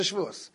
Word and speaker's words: Shavuos: Yeah, am I saying it right? Yeah. Masechta Shavuos, right where Kadosh Baruch Hu felt bad for diminishing the moon, so he needed to bring a carbon chatas.